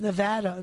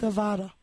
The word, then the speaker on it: Nevada: And you got to say Nevada, people. Apparently, we have all been saying it wrong for years. So it's Nevada, not Nevada.